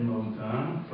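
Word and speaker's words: Moda (מודע): The word Moda is read Moda, but it's spelled Mem Yud Dalet Ayin, not Mem Vav Dalet Ayin.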